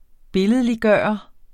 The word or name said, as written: billedliggøre